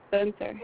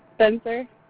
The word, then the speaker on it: Spencer: Spencer. – I'm sorry? – Spencer.